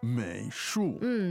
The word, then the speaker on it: 美术: 美术 third and fourth tone.